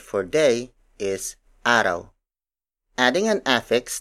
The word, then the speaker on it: araw: An example of a root word is araw.